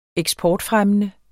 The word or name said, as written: eksportfremmende